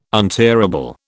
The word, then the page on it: untearable: British English